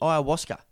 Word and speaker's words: ayahuasca: How do you pronounce it? – Ayahuasca. – Ayahuasca, there you go.